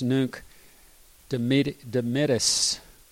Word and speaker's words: Nunc Dimittis: And so, especially if you're in some high church tradition, if you sang Nunc, I always mispronounce this, Nunc Dimittis, that's Latin for "Now let me depart." Nunc, now, N-U-N-C, Dimittis, depart.